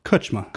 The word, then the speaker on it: Kucsma: Kucsma. – Kucsma? – Yeah, Kucsma.